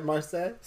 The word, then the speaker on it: Marseille: I don't know how to really pronounce Mercedes. – That. – Marseille. Let's just take it to the modern. – It's Marseille, or it's Marseille, right? Marseille? – Yeah. – Okay. C'est Marseille.